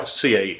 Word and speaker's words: CAE: CAE? – Yeah. – CAE.